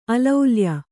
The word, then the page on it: ಅಲೌಲ್ಯ: alaulya